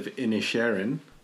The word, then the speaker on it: Inisherin: The two films were I still don't know how to pronounce this, the Banshees of Inisherin. – Yeah, pretty much. – White Noise, and we've watched the Harry and Meghan documentary as well. – We have indeed. The Banshees of Inisherin. – And that's how you say it. – I believe that's how it's pronounced.